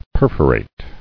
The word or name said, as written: Perforator